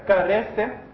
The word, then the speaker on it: charis: Está deletrada K-C-H-A-R-I-S. Y se pronuncia carece. ¿Y quiere decir esto esa palabra? La palabra gracia viene de la palabra charis. C-H-A-R-I-S o carece.